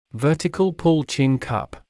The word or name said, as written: vertical-pull chin cup